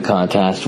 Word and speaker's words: contest: And we're now in the second round of the contest, which I think runs till September 28th. And yeah, I just realized I pronounced it contest, which, come on, learn how to talk.